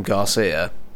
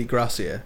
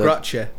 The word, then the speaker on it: Gracia: It's spelt Grathia, isn't it? But everyone calls him Garcia. – Oh, it's definitely Gracia. – Yeah, because... – Gracia.